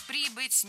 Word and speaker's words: прибыть: Коменданту или командиру корабля какого-то, который пришел из дальнего плавания, прибыть к императору на доклад и так далее. – Прибыть неверно, правильно прибыть.